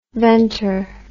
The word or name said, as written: ventr